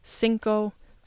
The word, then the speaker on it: singko: It is cinco. Again, it sounds exactly the same as the Spanish word cinco. Spanish, it's spelled C-I-N-C-O. And in Filipino, it is spelled S-I-N-G-K-O.